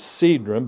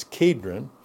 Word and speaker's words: Kedron: So usually in the Greek, the transliteration, it's Cedron, but it's Kedron.